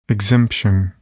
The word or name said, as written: exemption